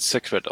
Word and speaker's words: Sacred: Du meinst, ich meine aber, glaube, das wird Sacred ausgesprochen. – Ja, oder so. Ja. Aber das war jetzt. Sacred von Ascaron, ja.